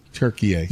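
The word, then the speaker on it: Türkiye: Turkey A. – Yeah. Turkey A. Yeah, there we go. Turkey A. – I don't know. – Turkey just decided to change the pronunciation of their country about 2 years ago.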